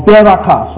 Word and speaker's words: berakah: B-A-R-A-K. And I'll make you a blessing is another word, Baraka. Or Beraka, sorry, Beraka. B-E-R-A-W-K-A-W. Pronounced B-E-R-A-K-A-H. Beraka.